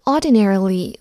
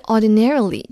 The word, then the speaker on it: ordinarily: 下一个副词 ordinarily 或者 ordinarily 这两种发音都可以。